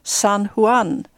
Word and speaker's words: San Juan: Jaså, men vet du inte att J uttalas som H i Spanien? Det blir San Juan.